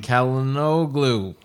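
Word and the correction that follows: Çalhanoğlu: Chalhanoglu. – You're right. C is J in Turkish.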